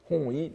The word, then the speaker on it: hoi: on prononce hoi, euh, hon-i, n'est-ce pas euh, Parfois aussi on peut prononcer honi, mais euh, dans, les, dans, les, textes médiévaux, plus souvent c'est hoi,